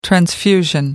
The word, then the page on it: transfusion: American English